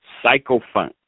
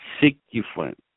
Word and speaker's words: sycophant: Now, today's word of the day is, let's see here, psychophant. Actually, the pronunciation is sycophant.